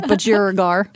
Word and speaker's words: budgerigar: So budgerigar.